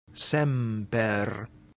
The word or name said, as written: semper